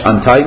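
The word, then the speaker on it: anti: در اون نظریه تقارن اینطور گفته می شد که هر ماده‌ای دارای یک پادماده است، یه آنتی یا آنتای، حالا تلفظ امریکنش آنتای میشه، تلفظ بریتیشش آنتی میشه، یه آنتی آنتای دارد، یه ضد خودش رو دارد.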